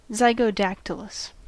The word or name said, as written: zygodactylous